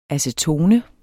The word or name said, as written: acetone